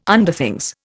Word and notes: Underthings: Indian

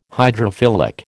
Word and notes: hydrophilic: American English

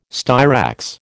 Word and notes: styrax: American English

